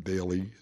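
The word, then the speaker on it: Dailey: My very first recording was for Columbia Records, and it was named after me, Daly Double.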